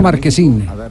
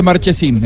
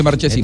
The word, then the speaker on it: Marchesín: ¿Ustedes lo pronuncian como Marchesino o, o, de origen italiano Marquesín? Eh, acá se le dice Marchesín, en el fútbol argentino se dice Marchesín.